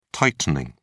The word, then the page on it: tightening: British English